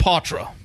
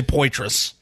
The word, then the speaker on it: Poitras: It is pronounced Poitras. I know everyone's going to say Poitras.